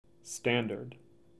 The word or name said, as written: Standard